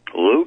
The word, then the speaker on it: Lou: But you can send money orders, checks made out to WWCR or Global Star Communications, or cash to me, Lou Caverly, and that's L-O-U, and the last name is spelled C-A-V-E-R, L-Y, at Post Office Box 28, Bethlehem, just like in the Bible, Bethlehem, Maryland, 21609.